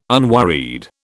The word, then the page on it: unworried: British English